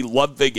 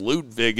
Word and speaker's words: Ludvig: Also, you can see Ludvig Aberg uh, I think it might actually be pronounced Ludvig, is how I heard people pronouncing it this week in the media center.